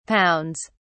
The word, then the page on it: pounds: British English